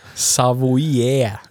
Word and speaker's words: Savoie: Savoie. Det är exakt så man uttalar det ja, också.